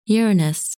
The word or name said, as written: Uranus